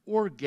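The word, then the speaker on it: orge (ὀργή): A very unique Greek word, orge, is how it's pronounced in the original Greek.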